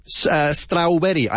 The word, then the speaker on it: strawberry: Strawberry. I hear strawberry a lot. It's strawberry. Strawberry. Brie. Brie. Yeah? Brie. Strawberry. Enfatizando la primera sílaba.